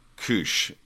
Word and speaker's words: Kusz: And her last name is spelled K-U-S-Z, so I believe that's Kush.